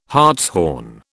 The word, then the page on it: hartshorn: British English